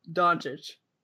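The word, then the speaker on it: Dončić: I see Doncic as, how do you say your name? I see Doncic. – Doncic. – Doncic. – I see Don before.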